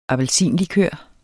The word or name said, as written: appelsinlikør